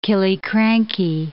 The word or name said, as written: Killiecrankie